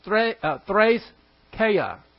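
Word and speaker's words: threskeia (θρησκεία): The Greek word for worship in this verse is threskeia. Threskeia. Actually, it should be pronounced threskeia. Eskeia. That's the two eidas in there. This is that's why you have e's, but they sound like a's.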